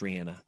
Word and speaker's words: Rihanna: Rihanna, now that I know her name is properly pronounced Rihanna.